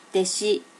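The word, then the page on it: deshi: native Japanese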